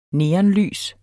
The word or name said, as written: neonlys